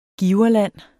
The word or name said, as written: giverland